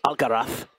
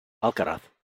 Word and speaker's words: Alcaraz: Medvedev, Monfils, Alcaraz. That's Alcaraz for the Castellano pronunciation. Alcaraz.